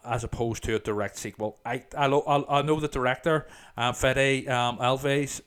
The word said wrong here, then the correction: Alvarez: Hope I'm pronouncing his name correctly. – Alvarez, has stated that it's set in the same universe as the original.